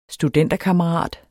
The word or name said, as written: STUDENTERKAMMERAT